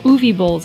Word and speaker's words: Uwe Boll: Oh, Uwe Boll's. That's who I was thinking of. I think that's how you say his name. But I think that's how you say it, yeah.